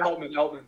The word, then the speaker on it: Altman: Altman.